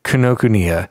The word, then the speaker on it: Kinokuniya: But my boss, whose wife is Japanese, told me that it's probably pronounced Kinokuniya.